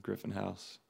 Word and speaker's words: Griffin House: I think Griffin Wade House is the Instagram, and the rest are Griffin House. – So, cool. Yeah. And it's spelled just like it sounds, right? – Yeah. Griffin, and like the house you live in.